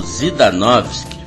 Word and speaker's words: Zidanowski: E vamos fechar então com um cara que eu conheci na festa do meu querido amigo e irmão, o Paulo Zidanovski. Para os amigos, é o Paulo Zidan, que se escreve Zidanowski. Paulo Zidanovski.